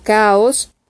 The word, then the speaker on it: Chaos: Ejemplo, se escribe chaos, pero vas a pronunciarlo como caos y la, la palabra chor se pronuncia cor, significa coro.